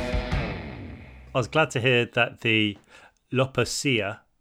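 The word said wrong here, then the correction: lopapeysa: was that how we pronounce it? The jumpers that we got when we were in Lopapesa. – Yeah. – Lopapesa.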